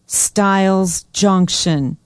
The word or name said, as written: stiles junction